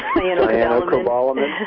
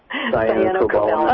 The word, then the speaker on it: Cyanocobalamin: Cyanocobalamin. – How do you say that? – Cyanocobalamin.